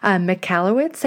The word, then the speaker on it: Michalowicz: I always say his name wrong. Michalowicz, I think is how you pronounce it.